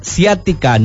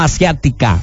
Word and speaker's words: ciática: Sí, Charal, se dice ciática, no asiática.